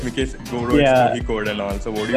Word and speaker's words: Kozhikode: Even I am not like 100% correct, but what I've learned is Kozhikode. The Z is silent. So Kozhikode.